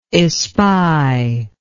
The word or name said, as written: espier